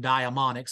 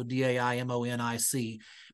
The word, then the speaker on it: daimonic: So daimon would be the Greek word diamond, and I'm saying daimonic, so daimonic.